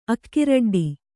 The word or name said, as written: ಅಕ್ಕಿರಡ್ಡಿ